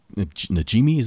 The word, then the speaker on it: Najimy: And they're doing a virtual one night only event where Bette Midler, Sarah Jessica Parker, Kathy Najimy, is that how you say her name? – Yeah.